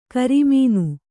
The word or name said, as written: ಕರಿಮೀನು